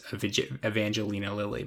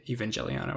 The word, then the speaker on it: Evangelion: I'm gonna pronounce it wrong just partially because I always call it Neon Genesis Evangelina Lily, but it's Evangelion or whatever.